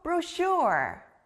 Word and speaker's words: brochure: 쉿! 소리 나는 또 하나의 단어를 배워볼게요. 바로 책자, brochure예요. 여기 가운데 쉿! 소리 들렸나요? 우리가 여행을 가면 아주 다양한 정보가 들어있는 책자를 받아요. 책자는 영어로 brochure.